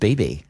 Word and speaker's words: Bebe: Is that not how you say it? – I thought it was just BB. – Oh, is it BB Rexha? – You're going Bebe Rexha. – I thought like, really, it was Bebe. Like, oh my God, Bebe.